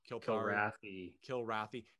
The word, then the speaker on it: Kilrathi: So I don't know what the case was, but there were supposed to be multiple conversations with the traitor and the Kilthari. Am I saying that right? – Kilrathi. – Hey, okay, Kilrathi.